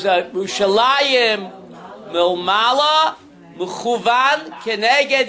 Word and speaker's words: Yerushalayim: And therefore, we pronounce the word Yerushalayim, even though it's spelled Jerusalem.